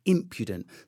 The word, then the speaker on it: impudent: So I knew it was impudent. So there's an accent on the I, impudent.